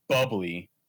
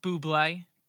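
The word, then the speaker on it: bubly: It's pronounced Bublé, but I won't Bublé. – Get it. – Yeah. Like Michael Bublé.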